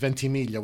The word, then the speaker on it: Ventimiglia: I think in Italian it's Ventimiglia, which means 20,000.